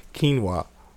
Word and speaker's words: quinoa: Quinoa. – You pronounced it perfectly.